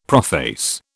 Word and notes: prophase: British English

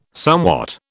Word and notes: somewhat: British English

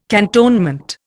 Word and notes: cantonment: Indian

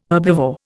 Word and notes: herbivore: Indian

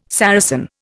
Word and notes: Saracen: Indian

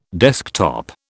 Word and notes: desktop: British English

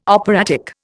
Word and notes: operatic: Indian

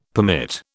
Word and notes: permit: British English

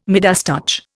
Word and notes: Midas touch: Indian

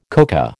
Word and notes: coca: American English

